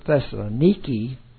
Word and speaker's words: Thessaloniki: When we were in Thessalonica, they pronounce it Thessaloniki, because one of the generals rebuilt the town and named it for his wife.